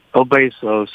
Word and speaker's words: Obesos: Well, I'll be pulling for you this weekend, and good luck to you and all your team, and of course the horse Obesos. Is that how you pronounce the horse's name? – Obesos. Yeah, it's a name. Besos is kisses in Spanish, and the dam of this horse is Snugs and Kisses.